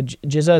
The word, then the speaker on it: jizya: And we won't impose like that tax, the jizya, is that how you pronounce it?